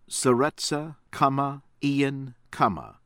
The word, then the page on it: Seretse Khama Ian Khama: American English